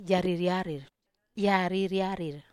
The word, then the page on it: aririarir: Wamesa